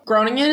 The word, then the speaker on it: Groningen: Groningen is a perfectly acceptable pronunciation,